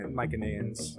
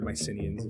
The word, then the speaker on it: Mycenaeans: We know they were centered on Crete, I believe, because there's Well, that's the Mycenaeans, technically, or Mycenaeans, if you want to call it like that. But the Greeks didn't have soft C sounds in their language.